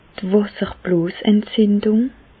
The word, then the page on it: d' Wàsserbloosentzindùng: Alsatian; Bas-Rhin; Reichshoffen